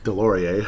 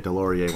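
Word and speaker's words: Deslauriers: But that's the difference when you bring in these big physical guys like Jacob Middleton and Delorier. I was going to mispronounce it again, but at least I got that one right. Who could forget Delorier when it rhymes with DeLorean?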